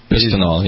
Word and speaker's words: bisphenol: Bisphenol, yeah. – Right. How do you pronounce it? – Bisphenol. – Bisphenol.